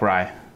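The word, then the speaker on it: Wry: And then Alec Rye, of course, our boy Alec Rye. I hope he said his name right. It's W-R-Y. It's like Rye, right? Rye? Alec Rye.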